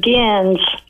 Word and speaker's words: Gens: Hey, my name is Gens. It rhymes with Benz, and I'm calling from...